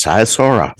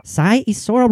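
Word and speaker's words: Sighișoara: Born in 1431 in Sai, Oh, remember, how it's pronounced? – Yes, Saisora. – Saisora, Romania.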